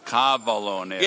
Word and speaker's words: cavallo nero: And it's, if I say this wrong, you have to be careful how you pronounce this, because if I say it wrong, you used to give me a hard time, because you say, that's a horse. – Yes. – Cavallo. – No, cavallo nero.